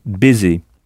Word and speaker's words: busy: Ça s'écrit B-U-S-Y, mais on prononce baiser. Donc avec le son B au début, exactement comme le mot business, qui vient d'ailleurs de baiser.